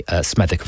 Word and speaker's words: Smethwick: Forgive me, I mispronounced that earlier, didn't I? Smethwick, of course.